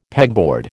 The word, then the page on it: pegboard: American English